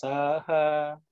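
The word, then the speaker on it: saha (सह): And the A is a short A. In this sa also, it's short A. So it's saha, saha, saha.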